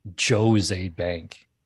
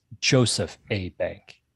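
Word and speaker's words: Jos. A. Bank: It's not Joe's Aid Bank. The Joe's is short for Joseph. It's Joseph A. Bank.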